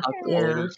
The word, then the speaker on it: Okoye: I think is that how you say it? – Yeah. – Okoye. – Yeah, I think.